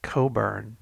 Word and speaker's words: Cockburn: Yes, it's spelled Cockburn and pronounced Cockburn.